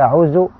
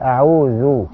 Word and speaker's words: أعوذ: أعوذ بالله من الشيطان الرجيم، ولكن يراعي القارئ ان هو يعني أعوذ بالله، يعني يظهر الحرف الذال بإخراج الايه اللسان، طرف اللسان عند نطقه، لا يقول أعوذ، قل أعوذ أعوذ.